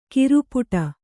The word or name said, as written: ಖುರಪುಟ